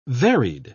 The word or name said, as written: varied